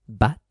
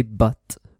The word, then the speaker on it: but: But, remember the pronunciation, you don't say bat. You know, that's a bate de baseball, bat or murciélago. You say but.